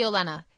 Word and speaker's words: Olenna: And I think that it's Lady Olenna is the pronunciation.